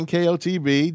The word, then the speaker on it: NKOTB: I said it wrong, and you corrected me the correct way. – There you go. – I said NKTOB.